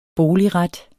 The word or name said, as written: boligret